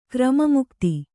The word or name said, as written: ಕ್ರಮಮುಕ್ತಿ